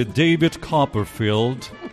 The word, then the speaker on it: David Copperfield: Queremos saludar, es obligado cumplimiento, a la oficina de representación de David Copperfield, gran... ¿Lo he dicho bien, Herrera? Es un... – Sí.